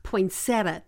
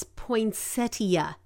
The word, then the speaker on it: poinsettia: he called me out on mispronouncing the word poinsettia, which I pronounced poinsettia. That's just how I've always heard it, poinsettia. And he was like, no, it's poinsettia, poinsettia.